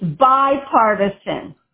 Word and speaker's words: bipartisan: But you're mispronouncing that word. It's bipartisan, not B. Bi.